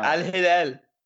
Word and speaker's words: Al Hilal: Sebastian Giovinco leaving for Al Hilal. Did I say that right? – Yeah, Al Hilal. Come on, man. You got to throw the accent in there.